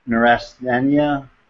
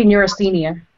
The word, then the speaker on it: neurasthenia: How do you say that word? You had the one that we were talking about beforehand, the neurasthenia. I can't even pronounce it. – Probably neurasthenia. – Neurasthenia, thank you.